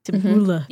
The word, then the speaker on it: tabbouleh: Tabbouleh? – Yeah. – Tabbouleh.